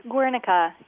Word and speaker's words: Guernica: She is an author of short fiction, collections of essays, and in July had a piece in, am I pronouncing it right, Guernica? – Guernica, yeah.